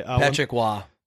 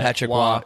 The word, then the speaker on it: Patrick Roy: For my bit, I got Pull the Fucker, a.k.a. Patrick Roy. – Patrick Waugh. – Is that how you pronounce that? – Patrick Waugh. So why do they spell a name like that? – See, he's French, so he was the ex-coach of the Colorado Avalanche, and I had to add that because he...